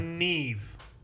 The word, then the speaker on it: Niamh: Neve.